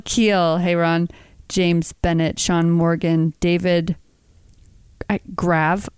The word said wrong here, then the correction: Gray: I can't. – David Gray. Yeah, David Gray.